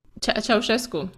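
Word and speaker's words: Ceaușescu: I mean, Romania, so that would have been I'm Cuscu, am I pronouncing that? – Ceausescu. It's a hard one.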